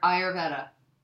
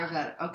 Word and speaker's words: Ayurveda: I, how do you say it? – Ayurveda. – Ar- Ayurveda. – Ayurvedic. – Yeah. Ayurveda. Okay.